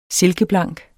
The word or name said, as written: silkeblank